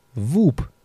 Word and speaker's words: Whoop: Und die heutige Folge wird präsentiert von Woop. Whoop schreibt man das Ganze.